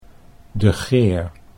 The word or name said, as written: De Geer